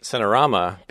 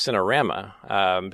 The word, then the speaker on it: Cinerama: Well, it's interesting that you pronounce it Cinerama, because the correct pronunciation is Cinerama, because it was a cinema panorama.